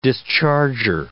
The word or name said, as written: discharger